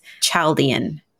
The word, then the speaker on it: Chaldean: and that is Chaldean numerology.